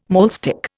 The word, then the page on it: maulstick: Indian